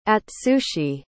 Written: atsushi

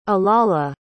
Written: alala

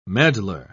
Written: meddler

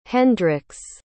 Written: Hendrixx

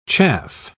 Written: chaff

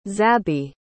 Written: zabby

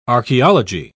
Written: archeology

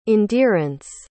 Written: endearance

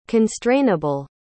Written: constrainable

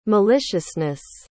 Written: maliciousness

